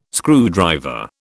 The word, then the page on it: screwdriver: British English